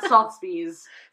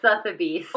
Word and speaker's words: Sotheby's: On sale now on Sotheby's. – Sotheby's? – Okay, did I say it wrong? Did I say it wrong? How do you say it? – Sotheby's.